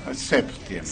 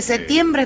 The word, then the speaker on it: septiembre: Con la P. – Sí, porque septiembre es como... – Sí, hay distintas maneras de pronunciarlo, ¿no? – Septiembre, es como... – septiembre. – No sé, suena raro, septiembre. – Uh-huh. – Es aceptado, bueno, ¿no? Pero me gusta más septiembre.